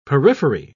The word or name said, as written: periphery